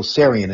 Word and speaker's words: Tsarion: Uh, his name is um, Michael Tsarion, and the, the T is silent.